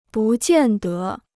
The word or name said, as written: 不见得